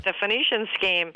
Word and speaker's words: Phoenician Scheme: How do you pronounce this? The, not Phoenician scheme. Am I saying that right? – You said it exactly right. The Phoenician scheme.